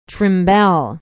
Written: trimbelle

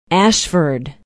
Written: ashford

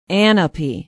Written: ahnapee

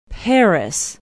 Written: paris

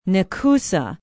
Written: Nekoosa

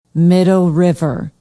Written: Middle River

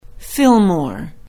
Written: Fillmore

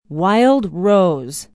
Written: wild rose

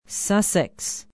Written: sussex

